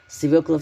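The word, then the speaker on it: свёкла: Несмотря на то, что буква «ё» в русском языке всегда ударная, это слово часто вызывает вопросы. Ошибка возникает из-за того, что многие пишут его неправильно, через «е». Запомните, свекла всегда пишется и произносится через букву «ё», других вариантов нет.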